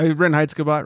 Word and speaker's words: Reinheitsgebot: Reinheitsgebot. Am I saying it wrong? – Yeah, Reinheitsgebot. Reinheitsgebot. I'm sure if you add that German accent, it's a boot.